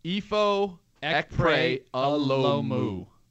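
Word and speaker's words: Ifo Ekpre-Olomu: So yeah, we got Ifo, and I just want to take a stab at it, because now we got the pronunciation in the press release. Ifo Ekpre Alomu. – Ifo Ekpre Alomu. Alomu. – Alomu. – Yep, I like it.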